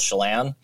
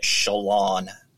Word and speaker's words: Chelan: We have a, a town north of me called Chelan and it was on a documentary I was watching the other day, and they were calling it Shalon.